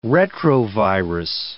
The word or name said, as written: retrovirus